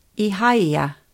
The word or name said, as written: Ihaia